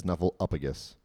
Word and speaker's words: Snuffleupagus: You said it wrong again, but that's okay. – And what did I say? – You said Snuffleupagus. – What should I have said? – Snuffleupagus.